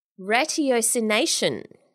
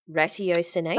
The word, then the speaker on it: ratiocination: Ratiocination. Ratiocination. – Ratiocination or ratiocination? Yes. – Well, I did look up how to pronounce it and there were conflicting reports.